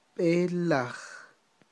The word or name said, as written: b'elaj